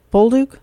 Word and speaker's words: Bolduc: Bolduc? Is that how you say his name? His campaign? – Yeah, yeah, yeah.